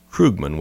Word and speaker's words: Krugman: And the number 1 top 10 thing I'm growing really tired of when I read articles about Bitcoin is Paul Krugman, whose last name is pronounced Krugman, which ironically rhymes with Krugerrand, and whose initials, ironically, are the same as public key.